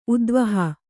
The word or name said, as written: ಉದ್ವಾಹ